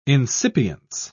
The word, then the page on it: insipience: British English